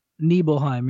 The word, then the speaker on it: Nibelheim: Nibelheim. Is that the right way to say it? Am I saying that right now? – Nibelheim, yeah.